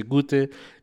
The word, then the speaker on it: Goethe: No se llama Goethe, se llama Goethe, pero... también aprenderán a pronunciar porque se dice Goethe.